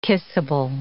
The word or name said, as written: kissable